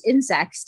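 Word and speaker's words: Ynsect: Yeah, there's actually looks like there's this French company called Insect that's spelled YNCT.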